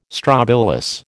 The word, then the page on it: strobilus: American English